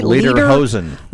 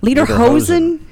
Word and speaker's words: Lederhosen: Lederhosen. – Lederhosen.